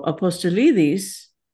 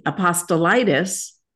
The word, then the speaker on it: Apostolidis: So Apostolidis, which means follower of the apostles, is lovely. Apostolitis, don't get hepatitis, vote for apostolitis, is not so pretty.